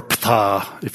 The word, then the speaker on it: Ptah: It's a manifestation of the creator god Ptah, or Ptah, that's a silent P.